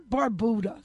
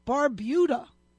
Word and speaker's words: Barbuda: and Barbuda. If any of you know the producer of any radio or TV show, somebody early on came out and said Barbuda. It's not Barbuda. Think Bermuda. It's Barbuda. Get people to say it right.